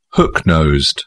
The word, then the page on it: hook nosed: British English